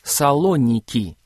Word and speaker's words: Салоники: Только сейчас это произносится «Салоники», а не «Фессалоники».